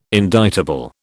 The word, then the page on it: indictable: British English